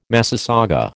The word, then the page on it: massasauga: American English